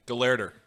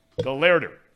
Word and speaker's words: Gelehrter: How do you pronounce his last name? – Gelehrter. – Gelehrter. – Yep.